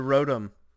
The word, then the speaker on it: Rotom: But anyway, the Rotom. I don't know how to say it. – Rotom? – Like a Tom Rotom. Tom.